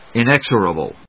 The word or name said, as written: inexorable